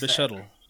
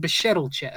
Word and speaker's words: Bechdel: How do you pronounce Bechdel. – That? Bechdel test.